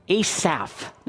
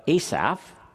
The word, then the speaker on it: Asaph: David wrote Psalm 37, and a guy named Asaph, now pronounce it correctly, it's not Asap, but Asaph.